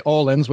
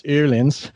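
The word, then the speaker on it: Öhlins: A lot of people say Orleans, which I used to say, right? But it's actually, as a Swedish company, it's Orleans.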